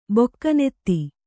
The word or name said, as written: ಬೊಕ್ಕನೆತ್ತಿ